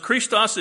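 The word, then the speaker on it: Christos (Χριστός): Pronounced Christos in the original Koine Greek, it is the equivalent of the Hebrew Messiah and means anointed of God.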